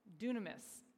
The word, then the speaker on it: dunamis: But this, our English word, dynamite, comes from the Greek word dunamis. Dunamis. I don't know how I'm saying this. Matt's looking back there. The new, uh, he gave me a thumbs up, so I guess I said it right.